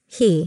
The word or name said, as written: He